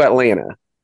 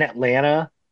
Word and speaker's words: Atlanta: Also, we, I mean, Quick aside, did you guys know the second T in Atlanta is silent? – Atlanta.